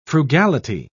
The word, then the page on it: frugality: British English